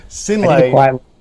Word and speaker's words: Synlait: And thank you also for correcting me on my horrible pronunciation of the company name. Synlait.